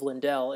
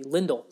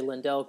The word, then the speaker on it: Lindell: You've got Jordy Benn with Steven Johns, and you have John Klingberg with Esa Lindell, which is, I've learned, is the actual pronunciation of Lindell. It's actually Lindell. That is interesting. – Yes, so I know I'll trip over that and I'll say Lindell quite a bit, but I'm going to try and Lindell like Kindle. So that's what it's pronounced, how it's pronounced.